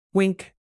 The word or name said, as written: wink